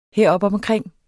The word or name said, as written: heropomkring